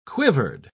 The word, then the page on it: quivered: British English